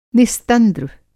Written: निस्तंद्र

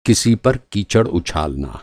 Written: कीचड़

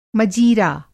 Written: मजीरा

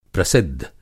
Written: प्रसिद्ध

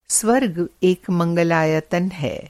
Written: मंगलायतन

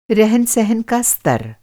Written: रहन-सहन

